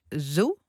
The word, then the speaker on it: Zhou: Een grote crash van de Chinees Zhou. Zeg ik dat goed? – Ja, daar zijn de meningen over verdeeld. Zhou, maar na deze crash kun je misschien beter zeggen Zhou.